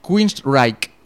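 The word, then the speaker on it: Queensrÿche: Sí, de hecho en su bio pone se pronuncia Queensryche. – Reich, ¿no? – Sí, acabado en K. Sería la transcripción fonética.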